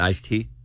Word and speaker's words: iced tea: So you just weren't, it wasn't you weren't pronouncing the D, you didn't think there was a D there. – No, it's iced tea. – I-C-E space. – It's iced tea. – T-E-A, okay.